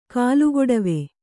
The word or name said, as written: ಕಾಲುಗೊಡವೆ